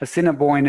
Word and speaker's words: Assiniboine: Uh, Assiniboine is what uh, the pronunciation thing I found.